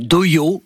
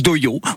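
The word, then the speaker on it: dohyō: Alors oui, on dit doyo, on prononce doyo, on dirait un peu un gars qui dit dojo mais qui a rendez-vous chez l'orthophoniste, mais c'est bien doyo.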